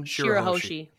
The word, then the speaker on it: Shirahoshi: Shirahoshi.